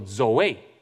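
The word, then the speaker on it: zoe (ζωή): 这个希腊原文的读音叫做 zoe，我把中文的音也翻写在那边，希望大家可以记得 zoe。那约翰很好，很有些好学生开始在从朗诵 zoe。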